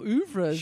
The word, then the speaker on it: oeuvre: I've seen most of the films, but I would love to say his oeuvre, but I'm pretty sure that's not how it's pronounced. – What? Is oeuvre? – Is oeuvre? – Yeah, that's right.